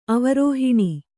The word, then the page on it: ಅವರೋಹಣ: avarōhaṇa